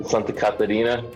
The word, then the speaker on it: Santa Catarina: uh, he was pretty cool guys from Santa, Santa Catarina. Is that how you pronounce it? – Yeah, correct.